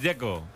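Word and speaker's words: Džeko: Bruno dice que es Dzeko. – Como tirando a J, Dzeko o algo así.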